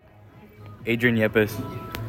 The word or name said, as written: Adrian Yepez